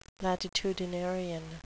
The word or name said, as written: latitudinarian